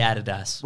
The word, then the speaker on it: Adidas: In Australia, we say Adidas. – We do. – Because it's the correct way to say it.